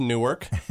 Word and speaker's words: Newark: Newark. So I know everyone around here knows, but that's about 45 minutes east, small city. Properly pronounced Newark, but everyone there, for whatever reason, likes to say Newark. It's, you know, it's kind of our thing. – Is that N-E-R-K, Newark?